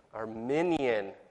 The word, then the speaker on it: Arminian: Arminian.